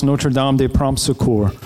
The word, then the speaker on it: Notre Dame de Prompt Succor: The Cajun people back home pronounce her name Our Lady of Prompt Succor. As a little boy, I thought she was a patron of the ones who couldn't find a date to the prom. Our Lady of Prom Succor. Our Lady of Quick Help, properly pronounced Notre Dame de Prompt Succor.